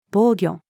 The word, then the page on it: 防御: female